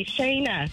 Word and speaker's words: Shayna: When I say Shayna, how's that wrong? – Because it's not Shayna. Are you a moron? – Just a long A. Shayna.